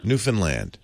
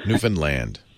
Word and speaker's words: Newfoundland: Newfoundland. How do we say it? – Newfoundland. – With an emphasis on land. – Yeah. – Newfoundland.